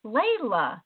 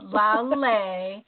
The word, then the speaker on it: Laleh: I keep wanting to say Layla, and it's not. Laleh, and it's spelled L-A-L-E-H for those of you listening.